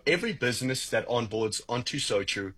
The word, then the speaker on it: SoTru: And so true.